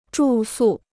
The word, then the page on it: 住宿: zhù sù